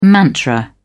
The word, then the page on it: mantra: British English